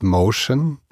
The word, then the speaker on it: Mocean: Nun, ich habe vor über 27 Jahren meine erste eigene Firma gegründet, die heißt Motion. Motion schreibt man vielleicht ein bisschen anders, als man denkt, nämlich Mocean.